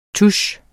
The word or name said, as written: TUSSEN